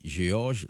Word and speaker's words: Georges: Mais là, mettons E, D, S, G, mais qu'on y a la vine, on le dit Georges, la vine Georges. – Alors, là, c'est un E muet. – Oui, donc puisque son E, on mettait après G, et puis on mettait O, Livine prononçait comme un J.